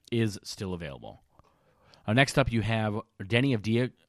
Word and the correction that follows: Deni Avdija: Deni Avdija, I should say, make sure pronouncing it right, uh, did end up going to the Bulls, which makes sense.